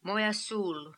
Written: Moyasul

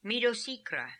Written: Midosikra

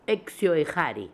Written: Exioehary

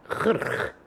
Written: Khrh

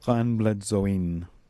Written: Hanbledzoin

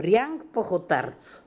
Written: Riank Pokhortarz

